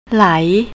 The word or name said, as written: lai